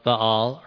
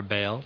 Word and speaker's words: Baal: This is Elijah's battle with and victory over the prophets of Baal, or Baal, depending who how you pronounce it, on the place called Mount Carmel.